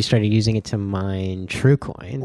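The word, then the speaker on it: TreauxCoin: T-R-U? – No, it's spelled like T-R-E-A-U-X. Like... – TrueCoin. It's a TrueCoin? – Sounds pretty elite.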